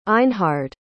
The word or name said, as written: einhard